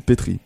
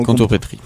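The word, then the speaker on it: contrepèterie: Ça s'écrit contre et ensuite péterie avec un E, mais on ne prononce pas le deuxième E, donc péterie en fait, mais pétrie.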